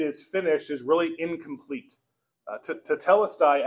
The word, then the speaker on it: tetelestai: It's a Greek word pronounced tetelestai. Tetelestai.